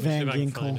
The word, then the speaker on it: Van Ginkel: G-I-N-K-E-L? How do you say that? – I think Ginkel. – Ginkel? – Van Ginkel. – Van Ginkel. Andrew Van Ginkel.